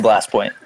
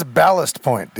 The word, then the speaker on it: Ballast Point: It's Ballast Point, dude. Ballast. That's how you pronounce that word.